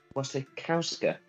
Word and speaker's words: Wasikowska: Mia Wasikowska. – Wasikowska, because the W is pronounced as a V.